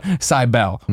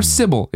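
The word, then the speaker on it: Cibele: I make fun of Tacoma and Steve Ginner a lot during this interview, but we're also talking a little bit about Cybele, or Sybil, if you want to pronounce it correctly, which I never do because I played the game, loved the game, and I call it Cybele. And if Nina wanted to pronounce it the correct way, she probably should have just written it a normal way.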